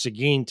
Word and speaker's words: Seguin: but it is Seguin, Texas, correct? – Yes, sir.